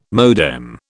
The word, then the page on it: modem: British English